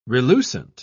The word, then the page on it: relucent: British English